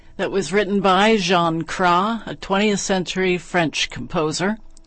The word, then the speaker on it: Cras: Next, the music of Jean Krah, a 20th century French composer. The last name is spelled C-R-A-S.